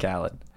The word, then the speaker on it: Khaled: I like to call myself the DJ Carlhead of hyper-specific home renovation shows. – I don't know who that is. DJ Khaled. – Have I been saying it wrong this whole time? – You don't know?